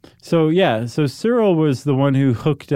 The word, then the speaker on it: Searle: S-E-A-R-L-E, Cyril? – Cyril. I want to hear you say it again. – Cyril.